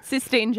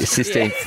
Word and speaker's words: Sistine: How do you say it? – Sistine Chapel. – Sistine. I put a th on the end. – Sistine.